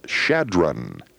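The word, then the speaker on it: Chadron: Thanks to Bob McCoy, who dropped a postcard to remind me that in Nebraska, it's C-H-A-D-R-O-N, and it is pronounced Shadron.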